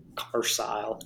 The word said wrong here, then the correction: Carise: Carise. Carise, yeah.